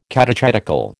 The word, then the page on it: catechetical: American English